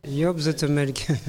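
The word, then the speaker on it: Joop Zoetemelk: Quelques mots peut-être de ancien bras droit de Raymond Poulidor et de, comment on prononce ? – Job Zoetemelk, c'est hollandais.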